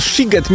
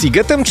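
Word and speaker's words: Sziget: Czy możemy ustalić, czy ten shiget, mimo że jest przez SZ, to jednak jest sigetem czy shigetem? – Oczywiście, ponieważ w języku węgierskim szy czyta się jak sy, a sy czyta się jak szy.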